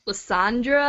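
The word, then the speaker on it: Lysandre: Lissandre? What's going on? – Lissandra, I believe, is the French pronunciation, so...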